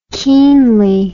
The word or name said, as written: keenly